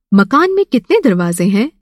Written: दरवाज़ा

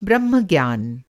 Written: ब्रह्मज्ञान

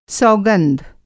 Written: सौगंध

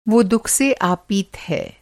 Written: आपीत

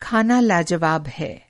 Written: लाजवाब

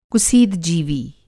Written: कुसीदजीवी

